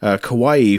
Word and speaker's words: Kauai: is it Kauai? Is that how you say it? – Yep. – Kauai for a job opportunity.